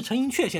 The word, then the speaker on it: 陈寅恪: 这个依据也比较坚实，因为他的提出这个外来说的学者呢，大家都耳熟能详，是陈寅恪先生或者陈寅恪先生，怎么读都可以。